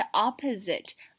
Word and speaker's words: opposite: 那我们首先来看第一个字呢是 opposite。 Opposite 呢是相反的，它的发音很特别，重音在第一个 o 的地方发 r，嘴巴上下拉开的感觉。